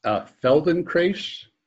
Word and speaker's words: Feldenkrais: Feldenkrais.